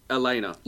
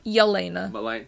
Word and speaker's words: Yelena: Elena. – Yelena.